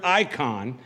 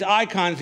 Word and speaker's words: eikon: The word for image there is the Greek word icon, and it's spelled E-I-K-O-N, but it's where we get our word for icon.